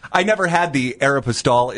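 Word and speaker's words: Aéropostale: Aeropostale. – Thank you! Yeah, she was not at Aeropostale.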